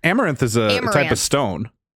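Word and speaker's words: Amouranth: It's Amaranth. – Oh. – What? Amaranth is how you pronounce it.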